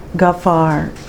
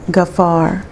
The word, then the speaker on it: Ghaffar: So the first word is Ghafar. Ghafar. It's spelled G-H-A-F-F-A-R. Ghafar.